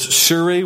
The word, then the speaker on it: Tsuri: Oftentimes you don't even hear the name or the T pronounced, but is Tsuri, which is the Lord is my rock.